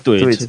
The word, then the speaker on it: Twitch: Twitch.